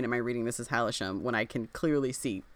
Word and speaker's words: Hailsham: The summary on Book Riot says Hailsham. – Oh, it's Hailsham. – It's Hailsham.